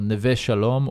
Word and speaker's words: Neve Shalom: Noam hails from quite a unique place in Israel called Neve Shalom or Wahat al-Salam. Probably doing an awful job pronouncing that. – It's actually pretty good.